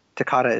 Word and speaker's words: Toccata: I have a quote here from him where he was talking about Toccata and Fugue.